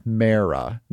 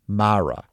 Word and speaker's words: Mara: The Mara family has always pronounced their surname as Mara, not Mara.